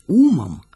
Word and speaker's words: умом: Это ангел-хранитель, переставший жить умом с ударением на «у», спрашивал, все ли у нас благополучно.